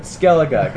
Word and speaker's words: Skellige: No, you said skeelage. – Oh. Fuck you, man. Skellige, goddamn, I know it's Skellige.